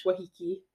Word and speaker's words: Waikiki: Waikiki.